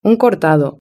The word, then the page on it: un cortado: European Spanish